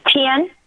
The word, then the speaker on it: Tian: T-I-A-N. How's that pronounced properly? – Tien.